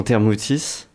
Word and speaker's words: Thermouthis: Vous, faites partie de, l'association Thermoutis, c'est comme ça qu'on la prononce.